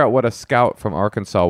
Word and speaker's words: Arkansas: That's how you pronounce it. Arkansas.